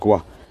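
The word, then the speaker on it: Khoi: It's pronounced Kwa. It's just spelled different, like K-H-O-I, K-H-O-E, but it's actually pronounced Kwa.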